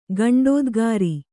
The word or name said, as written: ಗಂಡೋದ್ಗಾರಿ